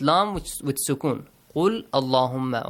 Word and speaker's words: Allahumma: And the word Allah, because of this, you pronounce the word Allahumma with a light sound. Allahumma.